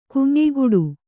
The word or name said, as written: ಗುಂಯಿಗುಟ್ಟು